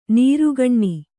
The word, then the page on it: ನೀರುಗಣ್ಣು: nīrugaṇṇu